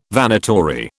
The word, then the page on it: Vanitory: British English